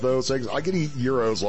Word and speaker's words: gyros: And just so you know, did you hear how I pronounce that? It's a Greek word. It is not pronounced gyros or gyros or gyros. The G is silent in this delicious Greek dish. Google it and see for yourself.